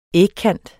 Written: ægkant

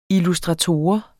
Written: illustrator

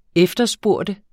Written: efterspørge

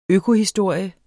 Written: økohistorie

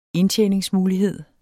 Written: indtjeningsmulighed

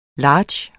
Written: large

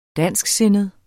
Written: dansksindet

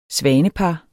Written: svanepar

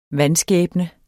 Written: vanskæbne

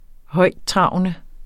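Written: højttravende

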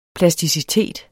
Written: plasticitet

